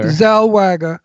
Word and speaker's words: Zellweger: Zellweger.